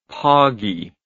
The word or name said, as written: poggie